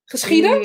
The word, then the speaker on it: geschieden: u wil zijt geschieden. Zeg ik het goed? Geschieden, ja.